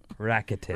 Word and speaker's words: Rakuten: Rakuten.